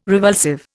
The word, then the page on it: revulsive: Indian